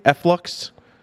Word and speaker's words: Efflux: Did I say that correctly? Efflux? You did. Okay. That is, uh, that's the actual pronunciation.